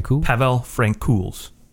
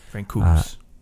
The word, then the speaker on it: Francouz: I mean, goaltending wise, Colorado was, it got interesting because Pavel Francouz. – That's how you say it. Yeah. – Francouz.